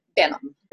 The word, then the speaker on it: Benham: Benham.